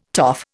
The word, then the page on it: toff: Indian